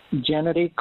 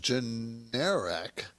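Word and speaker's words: Generac: I think it's pronounced generic. It's an AC at the end. Holdings.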